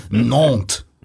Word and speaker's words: Nantes: This is the average goals per game of, this is my pronunciation, my French, Nantes. Is that right? – Strong.